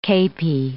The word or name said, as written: kepi